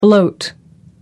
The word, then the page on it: bloat: American English